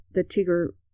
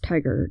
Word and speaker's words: Tigre: Yeah, but like the Tigre River, T-I-R... – I have no idea. – T-I-G-R-E, Tigre River. – I don't know that. – Because it's not pronounced tiger, it's tigre. – It's spelled tiger. – T-I-G-E-R? – Well, T-I-G-R-A.